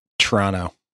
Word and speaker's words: Toronto: Yeah, most people here kind of call it Toronto. Like Toronto. They don't emphasize the T's as much as you would think.